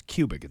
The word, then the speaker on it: Quebec: Quebec. – No, I believe it's Quebec. It's pronounced like pubic.